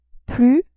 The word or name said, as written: plus